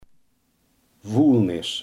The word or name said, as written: vuilnis